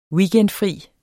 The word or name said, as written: weekendfri